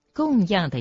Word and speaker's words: 供养: 一般人不但对待众生不会先研究，连供养佛也很少先研究了解，佛是应当如何供养？我们平常都听说供养佛、供养法，供养的样读作第四声。自古这个养字，对上要读作供养、孝养，第四声，对下才读作第三声养，比如教养、养育。